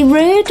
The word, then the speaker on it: Roode: It said that Bobby Roode, have I said that right? – Yeah.